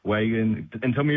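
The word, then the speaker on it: Wagin: Now, those G's, mate, they're the soft G's, so Wagon and Narrogin.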